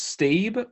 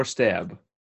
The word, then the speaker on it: Staab: Um, is it pronounced Stabe or Stab? I think it's Stab.